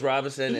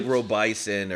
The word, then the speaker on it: Robinson: They used to call me like Robison or whatever.